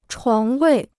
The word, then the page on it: 床位: chuáng wèi